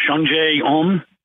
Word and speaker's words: Sungjae Im: You got to help me with the pronunciation. Sungjae Im. – Yes.